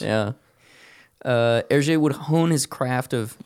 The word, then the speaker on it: Hergé: So, before he lands on his iconic Hergé pseudonym. He was using the previously mentioned pseudonyms. He created the pseudonym for Hergé by flipping his initials and then using the French pronunciation for them.